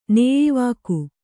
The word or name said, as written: ನೇಯಿವಾಕು